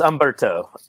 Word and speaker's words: Humberto: So the H is silent. It's Humberto.